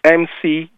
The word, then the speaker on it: McGonigal: McGonigal, I hope I'm pronouncing it right. It's McGonigal. She's a psychologist, G-A-L?